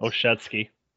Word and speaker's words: Olszewski: We grab a guy you're familiar with, Gunnar Olszewski from the New England Patriots. – Olszewski.